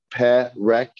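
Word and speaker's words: Perek (פרק): Perek. Is that right or wrong? – Wrong. – Is it Perek? Also wrong. Is it Perek? Yes. Two segols. Eh and eh. Perek.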